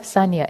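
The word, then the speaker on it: saññā: And then the concept of sanya, S-A-N-N-A, it's spelled in Pali, and perception is kind of different from how the psychological science in the West defines perception.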